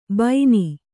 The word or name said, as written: ಬೈನಿ